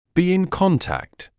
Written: be in contact

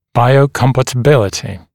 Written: biocompatibility

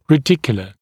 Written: radicular

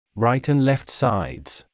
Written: right and left sides

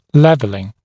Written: leveling

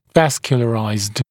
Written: vascularized

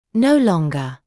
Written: no longer

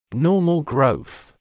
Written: normal growth

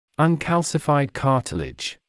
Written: uncalcified cartilage

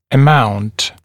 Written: amount

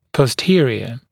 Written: posterior

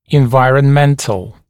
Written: environmental

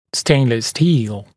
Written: stainless steel

